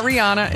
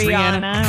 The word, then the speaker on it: Rihanna: It's not Rihanna. It's Rihanna.